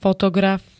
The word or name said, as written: fotograf